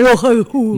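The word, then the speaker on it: Rohayhu: No, rápido es Rojaijú, pero no I.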